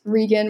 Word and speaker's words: Regan: So honestly, I'd say my Twitter @Regan with 2 E's @Regan, not Reagan. So spelled phonetically. I think people haven't really like gotten that.